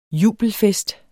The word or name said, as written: jubelfest